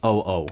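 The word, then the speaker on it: O.O: And as I say this, thank you very much to our producer and our staff, because NMIXX, the song is pronounced O-O.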